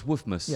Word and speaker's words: Woofmas: Sure if it's Wolfmas because it's wolf. – Woof. – Oh, it's Woofmas. – Yes. – If I could say it right. Oh, because that's the noise a dog makes.